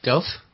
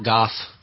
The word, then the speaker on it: Gough: Used to live on, how do you pronounce it, Gough? – Gough or Goff? – Like the painter. – Go? – Because the locals Dutch, call it the local.